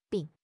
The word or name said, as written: bi3